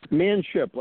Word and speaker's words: Manship: Manship, like a man on a ship.